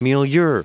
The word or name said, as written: milieu